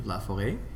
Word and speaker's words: Laforet: It actually comes from the French pronunciation of Laforet. And it's actually a little Chinese character, an accent circonflexe, as we say, on the E, because it's an abbreviation for LaForest.